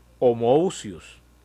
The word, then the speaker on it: homoousios: Usan una palabra en griego que se pronuncia homoousios, se pronuncia, que quiere, para referirse a Cristo, que quiere decir que es de la misma sustancia que el Padre.